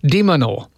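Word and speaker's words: de Minaur: I always say his name wrong. Dimenor in the All-Australian match, depending on whether or not he wins his first round match.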